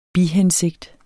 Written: bihensigt

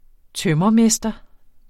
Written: tømrermester

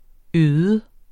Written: øde

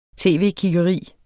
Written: tv-kiggeri

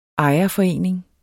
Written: ejerforening

